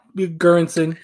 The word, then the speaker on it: Göransson: And the work of Ludwig Göransson. I think I said his name right. – Yeah, Göransson, yeah.